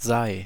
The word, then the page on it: sei: /zaɪ̯/